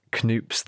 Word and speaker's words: Knoop: And I noticed that you said Knoops then,